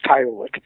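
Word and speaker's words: Kyolic: The pronunciation, is it chiolic or is it chiolic? I think I know the answer, but you know, I want to hear it from you. – I've heard it. I've heard it every single way. Chiolic. I pronounce it chiolic.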